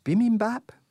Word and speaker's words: bibimbap: Do stay with us, because we're going to take a quick break now for a bibimbap, I believe it's pronounced.